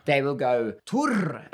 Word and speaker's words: Thor: Yeah, but I don't like when people do that either, because it was just a thing when I moved there, people, because it's spelled Thor, T-H-O-R, so yeah. People go, Thor. And then when sometimes people are like, how do you say Thor? And, you know, we roll our R's. – Yeah. – In Norway, so then I go Tor, and then they go Tor.